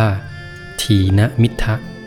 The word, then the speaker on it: ถีนมิทธะ: ทีนมิทะ